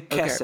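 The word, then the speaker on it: Keser (כתר): We're going to say Keser. Okay. Keser makes it so much Keser. – Easier. Keser, because you're gonna do the more Eastern European pronunciation, so it doesn't do the.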